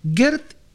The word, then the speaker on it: Geert Chatrou: Gert, en realidad es, porque parece que se pronuncia Gert, pero es doble E. G-E-R-T.